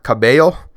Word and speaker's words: Cabello: I took 2 years of high school Spanish, and as far as I know, a double L is pronounced with a Y. Camila Cabello.